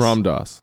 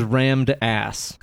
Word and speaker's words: Ram Dass: His name is spelled R-A-M-M. – Mm-hmm. – Last name D-A-S-S. – Ram Dass. Yeah, I know him. – I read that as rammed ass. – First off, didn't know Ram Dass died today. R.I.P. Ram Dass.